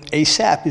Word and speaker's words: Asaph: And the person we're going to speak who wrote Psalm 77 was called Asaph. Now, I had to Google how to pronounce Asaph because I kept thinking Asaph, the F was silent. But you know, Asaph is not it.